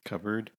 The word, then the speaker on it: cupboard: Cupboard. The P is silent. – Yep. Cupboard.